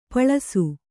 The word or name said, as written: ಪಾಲಿಸಿ